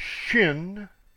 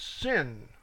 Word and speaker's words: Shin: Number 21 is Shin. Now there's two pronunciations of that. One is Shin, one is Sin, without the H.